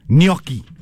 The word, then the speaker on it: gnocchi: Gnocchi.